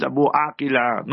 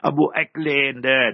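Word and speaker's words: Abu Akleh: The name is Abu Akila, not what the Westerners say. Abu Akland, they distort it. It is Shirin Abu Akila, A-A-Q-I-L-A-H. That is the proper way of spelling it, pronouncing it.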